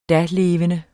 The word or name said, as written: dalevende